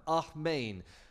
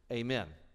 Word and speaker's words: amen: But the twist is, the root word is an ancient Semitic term, ahmein. Since you people go to church, you likely have heard this word. We pronounce it amen, okay? Amen.